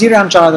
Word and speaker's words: Irian Jaya: Is Irian Jaya the right pronunciation? – Yes, it is, actually.